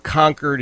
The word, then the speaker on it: Concord: Depending on where you are, the name is pronounced Concord, as it is in New Hampshire, or Concord in North Carolina. I learned that when I was in North Carolina, man. I didn't know better. I said Concord. They said, no. We pronounce it Concord. And that is a reminder that we were never conquered.